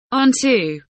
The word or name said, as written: onto